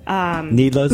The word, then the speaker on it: Nedloh: And actually, Nedlow's was one of my favorite Nedlow's? Booths. Did somebody Nedlow pronounce it? – Yeah. I don't know. N-E-D-L-O-H.